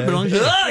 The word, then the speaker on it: Boulanger: Original pronunciation of your last name, if you're French Canadian, would it be Boulanger? – Boulanger, yeah. – Okay, it really would be. – Je m'appelle Mathieu Boulanger.